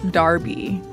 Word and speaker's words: Derby: Derby's First 50 Years. Also, I would like to note that Derby is pronounced Derby in the United States, but in Britain is pronounced Darby.